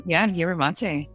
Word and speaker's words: yerba mate: It's a green tea with yerba mate. Did I say that right? – Yeah, yerba mate.